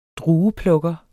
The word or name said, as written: drueplukker